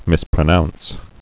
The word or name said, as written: mispronounce